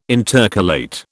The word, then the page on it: intercalate: British English